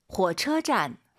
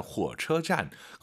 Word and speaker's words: 火车站: 후오가 아니에요, 여러분. 후어예요. 잘 들어보세요. – 火. – 네, 火车站. 그리고 이 차 발음하실 때 처 해도 알아들어요. 근데 안 예뻐요. 두 글자라 생각하시고 车 들어볼게요. – 车.